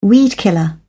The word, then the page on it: weedkiller: British English